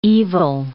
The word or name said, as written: evil